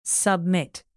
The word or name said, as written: submit